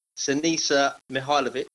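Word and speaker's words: Siniša Mihajlović: Number 4, now this is going to come back to haunt me a few times, but the pronunciation, but Sinisa Mihailovic.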